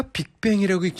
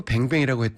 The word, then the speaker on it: 빅뱅: 제가 아까 빅뱅이라고 읽지 않고 뱅뱅이라고 했다는 우리 PD 얘기가 있는데, 제가 빅뱅 이랬는데 잘못 들은 거 아닌가? 죄송해요, 빅뱅. 승리의 함성 들었습니다.